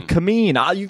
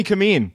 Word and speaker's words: Kameen: Kameen. Here's how I wrote it phonetically. – That's how I do it. – K A H dash M E A N.